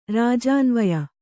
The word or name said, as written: ರಾಜಾನ್ವಯ